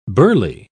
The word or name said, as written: burley